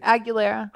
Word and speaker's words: Aguilera: Aguilera. – Christina Aguilera.